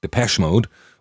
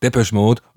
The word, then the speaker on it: Depeche Mode: Ja, so spricht man das aus, Depeche Mode und nicht Depeche Mode oder so, auch wenn sich das eingebürgert hat und auch Spaß macht, das so zu sprechen manchmal.